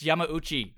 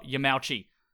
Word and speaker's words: Yamauchi: It's terrible. It's Yamauchi, not Yamauchi. Ah.